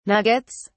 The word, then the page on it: nuggets: British English